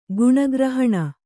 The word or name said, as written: ಗಣಗ್ರಹಣ